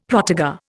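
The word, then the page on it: protégé: Indian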